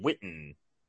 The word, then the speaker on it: Whitten: Witness, W-H-I-T-N? – No, his last name is spelled W-H-I-T-T-N, Whitten. – W-H-I-double-T-N, Whitten. And his middle name is? – Moss, M-O-S-S.